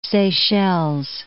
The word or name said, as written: Seychelles